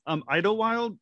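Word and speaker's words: Idyllwild: Well, what I found fascinating about you is that you attended, I might pronounce it wrong, it wouldn't be the first, Idlewild Arts Academy. Am I pronouncing that correctly? I am. – Yeah.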